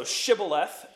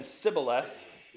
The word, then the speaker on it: Shibboleth: So Shibboleth and Sibboleth. Shibboleth is the word for river. They're trying to cross the Jordan, right, to get away from them. And they can figure out who the Ephraimites are because they can't say the word right. All right, they can tell where they were born because of how they pronounce it.